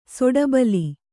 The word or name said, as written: ಸಿಡಿಬಲೆ